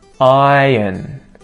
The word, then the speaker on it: iron: Number 13, iron. Iron. In this example, I don't pronounce the R. I would say this like iron. Iron. And there's a Y in there. Iron. A Y sound.